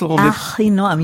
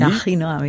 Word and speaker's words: Achinoam: Alors, comment ça se prononce? – Achinoam, you should be able to do it. – Achinoam, Nini. – Achinoam, yes.